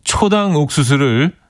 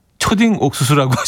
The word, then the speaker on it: 초당 옥수수: 뭐 오늘 뭐 이제는 말할 수 있다 그런가 이제 뭐 아, 그, 윤서윤이 대디를 윤서윤 이대디 초당 옥수수를 초딩 옥수수라고 하셔서 깔깔 웃으면서 운전한 적이 있어요 하셨습니다.